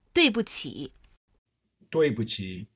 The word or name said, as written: duibuqi